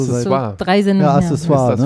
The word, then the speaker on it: Accessoire: Wir buchstaben A C C E S S O I R E. – Accessoire heißt es mit einem K ausgesprochen.